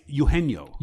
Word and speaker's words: Eugenio: Eugenio, is that how you pronounce the name? Eugenio? – It's Eugenio.